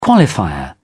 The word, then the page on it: qualifier: British English